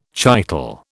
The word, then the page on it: chital: British English